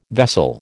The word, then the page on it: vessel: American English